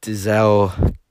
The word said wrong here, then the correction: Denzel Curry: or was it, how do you say his name again? Dizelle Curry, Denzel, that's it, Denzel Curry.